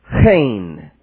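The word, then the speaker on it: chen (חן): Favor in 3:4 is chen, is how you say it in Hebrew.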